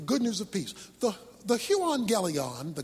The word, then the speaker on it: euangelion: The Greek word for gospel is the word euangelion. That's how it's pronounced.